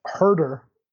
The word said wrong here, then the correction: Huerter: Kevin Huter. – Who Huter. – Has just been Huerter. That's the R that just throws me off.